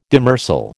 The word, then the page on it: demersal: American English